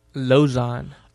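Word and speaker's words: Lauzon: Lozon.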